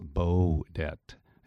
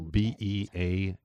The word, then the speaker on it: Beaudette: It's a French name. It's Beaudette. So it's Beaudette. Bruce Beaudette.